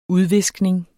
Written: udviskning

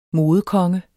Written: modekonge

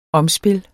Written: omspil

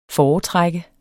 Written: foretrække

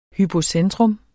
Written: hypocentrum

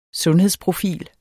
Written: sundhedsprofil

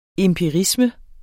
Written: empirisme

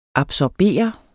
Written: absorbere

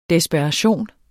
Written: desperation

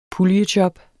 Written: puljejob

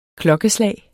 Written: klokkeslag

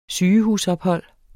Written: sygehusophold

